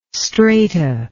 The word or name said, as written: strata